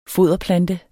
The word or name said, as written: foderplante